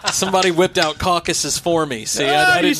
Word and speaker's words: Caucasus: Caucasus Mountains. There you go. – Caucasus.